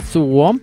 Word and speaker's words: Suwon: Se va a enfrentar contra Corea del Sur en Suwon.